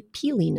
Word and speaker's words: pili: And so my oncology nutritionist, she recommended to me this nut called a pili nut. Now, this is spelled P-I-L-I, and it is pronounced like P-E-E-L-Y, pili.